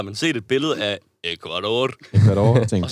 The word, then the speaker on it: Ecuador: det er Ecuador. – det er Ecuador.